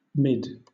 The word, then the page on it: mid: British English